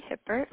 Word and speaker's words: Hippert: This is Stacy Hippert.